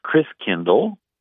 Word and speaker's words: Christkindl: And the German pronunciation of Christ child is Christkindl, a kindergarten, kindercare, kind means child. And over the years, Christkindl got pronounced Chris Kringle, right?